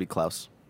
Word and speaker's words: Klaus: Really quick, I think that's supposed to be Klaus. – What's that? – It is Klaus. Yeah. You're right. Yeah, not yeah. Klaus. – Just pronunciation.